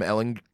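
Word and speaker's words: Ehlinger: I haven't seen it from Sam Ellinger. – It's a hard G.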